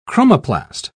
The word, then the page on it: chromoplast: British English